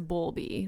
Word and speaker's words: Bowlby: I'm pretty sure it's Bowlby. It's because it's spelt like bowl, like a cereal bowl.